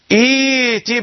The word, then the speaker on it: ائت: إذا كنت في مسابقة أو امتحان وطلب منك أن تقرأ تبدأ بهذه الكلمة، أن تبدأ بهذه الكلمة فقل إيتي بقرآن، ولا تزد على ذلك، لا تقرأ إيتي بقرآن غير هذا، لا تقرأ هكذا، البعض يقع في هذا الخطأ.